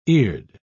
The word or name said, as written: eared